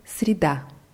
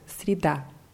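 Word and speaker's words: среда: Mittwoch heißt Srida. – Srida. – Ja. Das Wort wird mit je in der Mitte äh, geschrieben, aber je wird als i ausgesprochen, weil es unbetont ist. Srida.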